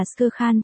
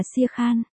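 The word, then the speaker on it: Shere Khan: Sia Khan nên được phát âm là Skur Khan trong cách ghi chép Rudyard Kipling, tuy nhiên trong bộ phim truyền thể tên của con hổ này luôn được phát âm là Sia Khan.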